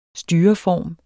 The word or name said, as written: styreform